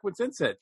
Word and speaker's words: Wincentsen: Eric Wincentsen.